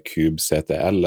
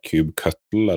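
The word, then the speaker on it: kubectl: Men nå har det blitt bakt inn, så det er jo Erik, du nevnte jo dette Cube CTL, eller Cube Cuttle, eller Cube Control. Det er den samme, bare forskjellig uttale, samme navnet.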